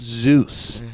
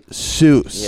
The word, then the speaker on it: Seuss: not Zeus, but young Seuss, yeah.